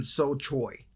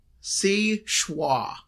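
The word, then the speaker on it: Seul Choix: Well, I would have thought, had I not looked up the pronunciation, that it would have been so choy. – That's what I was trying to say. – Right. Well, you're still wrong. It is si schwa. It's French.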